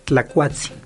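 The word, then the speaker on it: tlacuatzin: En náhuatl se pronuncia tlacuatsi Casi es igual, pero no, no es diferente. – En náhuatl, en es tlacuatsi en Huichol tacuatsi Okay.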